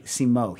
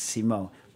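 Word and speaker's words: Simó: The guest judge was Jeffrey Morgenthaler, who you know from Portland, and this week it was Joaquin Simo from it's actually Simo. He told me, he's like, you've been pronouncing it wrong like since I've known you. It's Simo, Joaquin Simo.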